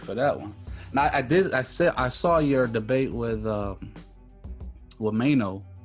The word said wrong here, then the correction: Miano: Did I say his name right? – Michael Miano. – Miano.